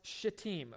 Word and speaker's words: Shittim: Your Bible may say Shittim. It's pronounced Shittim, okay? Don't get any ideas and pronounce it a different way. We're in church.